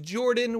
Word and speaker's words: Jorphdan: My name is Jordan, with a silent PH in the middle, and I am joined always by my wonderful co-host, Sir Lucian, over at Sir Lucian Gaming.